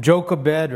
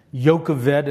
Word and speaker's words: Jochebed: And so we see Moses' parents, Amram and Jochebed, or Jochebed as they would have pronounced her name,